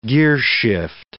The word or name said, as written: gearshift